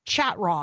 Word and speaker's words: Chatraw: It, his last name is pronounced like Chateau with an R in the middle, but it's mm-hmm. spelled mm-hmm. Chatraw because I want you to be able to find him.